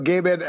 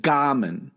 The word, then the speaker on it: Gaiman: Because on the Comic Book Club podcast, you said Gaiman, and now you're saying Gaiman. Which Gaiman. Fucking? – It's Gaiman. If I said Gaiman, I mispronounced it. It's Gaiman.